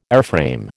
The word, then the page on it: airframe: American English